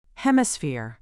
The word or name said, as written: hemisphere